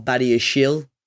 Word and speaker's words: Badiashile: Alex Teles on loan from United, and Benoit Badiashile, Joe's taught me how to pronounce that surname, uh, came in from Monaco.